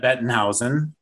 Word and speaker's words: Bettenhausen: And today we are here with Hannah Bettenhausen. Now, I know that's the German pronunciation. What's the English pronunciation, Hannah? – Same thing.